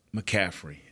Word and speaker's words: McCaffrey: First of all, this going against what a lot of people think is once you get past McCaffrey, so I'm pronouncing that right. – Yes.